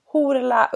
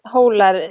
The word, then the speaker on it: Hólar: nu ska vi se om jag lyckas uttala det här rätt nu då, Horla University College. Ja, precis. Horla University College som ligger på Island.